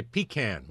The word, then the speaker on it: pecan: We made 8 pecan pies a day, and they flew out the door. – And you said it right. You didn't say pecan, like I hear a lot around here.